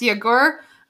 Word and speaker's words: Yagur: So if anybody is wondering what the name of that is, as Klein said, it's Yagur. It's just south of Haifa. It's spelled Y-A-G-U-R.